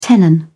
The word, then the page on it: tenon: British English